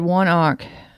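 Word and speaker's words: ONEOK: Fire at One Ock. Is that how that's pronounced? – Yes.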